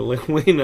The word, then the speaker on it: Len Wein: The, meeting Len Wein was big too. Is that how pronounced? – Len Wein.